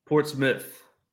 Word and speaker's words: Portsmouth: Portsmouth, but spelt mouth. – It is spelled mouth, yeah. – All right, I'm not stupid. No, I ain't stupid. – You're good. – That's how dumb that city is. It's said Portsmouth, but it's spelled Portsmouth, but said Portsmouth. – That's how we know if you're an insider or an outsider.